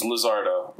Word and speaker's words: Lizardo: And then my last name is Lizardo, like lizard with an O at the end.